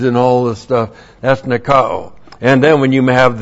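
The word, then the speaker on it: nikao (νικάω): So there's the article, and this is the participle, N-I-K-O, long O-N. This is from the verb nikao. N-I-K-A, long O.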